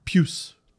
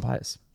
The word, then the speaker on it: Pius: Well, is it Puce? – Puce. – Oh, I've actually heard people call him Pius. – I know. – Puce. – Because that's how it's spelled. But Puce Suter.